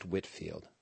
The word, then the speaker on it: Whitefield: So it's spelled Whitefield, pronounced Whitfield.